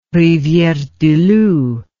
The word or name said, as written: riviere du loup